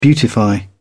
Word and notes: beautify: British English

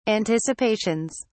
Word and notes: anticipations: British English

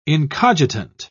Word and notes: incogitant: British English

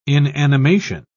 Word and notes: inanimation: British English

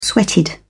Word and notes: sweated: British English

